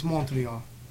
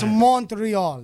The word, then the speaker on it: Montreal: And say it correctly. It's Montreal. – Montreal. – It's Montreal.